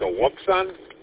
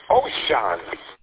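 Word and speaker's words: Wouxun: It's like a Wuxon? – Yes, but they say they pronounce those Ocean. – Ocean, like the seven seas.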